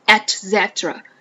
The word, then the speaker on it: et cetera: 好，我们看那下面 ETC 啊， ETC 就是等等的意思，但注意这个词的发音，经常很多人因为它是一个拉丁文啊， et cetera，虽然它缩略写成 ETC 啊，就是等等等等，但是念的时候我们要念出它完整的发音，这个是拉丁文的发音，念成 et cetera et cetera， asking if a meal is vegan, dairy free, gluten free, et cetera。